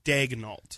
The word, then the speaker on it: Daigneault: I have the pronunciation guide now from Casey Thunder. It's D-A-Y-G. – Daig. – Dash Nolt. – So it is Daigneault. – Daigneault. – So it's, Daigneault. So then it is how it's spelled.